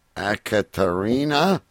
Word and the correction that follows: Ekaterina: Makarova. The more beers I have, we should get these stories out of the way early. Ekaterina Makarova. There you go.